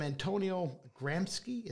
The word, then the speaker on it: Antonio Gramsci: But I think about the quote from Antonio Gramsci.